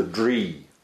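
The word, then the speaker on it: dri: and the female is actually called a dree.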